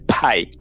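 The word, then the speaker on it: pie: Você pronunciou sem o aspiração que a gente tem no P em inglês. Então, em inglês, na verdade, não é pai, é pai.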